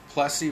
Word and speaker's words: Plessy: Am I saying that right? Yeah. Plessy versus Ferguson, 1896, the Supreme Court ruled that separate but equal schools for black children was okay.